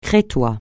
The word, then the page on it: crétois: francais